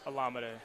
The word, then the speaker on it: Olamide: Alameda. – Alameda Zaccheaus. All right. So he even told us, Sturge. He told us it was Alameda. – I put the wrong emphasis on the wrong syllable.